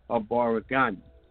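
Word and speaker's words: Habari gani: Now, there is a traditional greeting that you use during the Nguza Saba, which is a Baragani, which is spelled H-A-B-A-R-I, and the word Gani, G-A-N-I.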